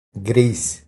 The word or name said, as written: Gris